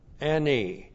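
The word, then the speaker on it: Ani (אֲנִי): That's Aleph, A, N, long I. Ani.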